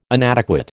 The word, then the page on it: inadequate: American English